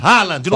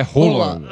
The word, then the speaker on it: Haaland: A pronúncia lá na Noruega é rola. – Roland.